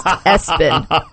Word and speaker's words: ESPN: ESPN pronounced Espen.